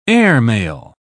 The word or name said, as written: airmail